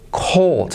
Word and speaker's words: cold: Cold. Fíjate, se hace como se aspira. Cold. Cold. Y la L casi no se pronuncia, pero está ahí. Cold.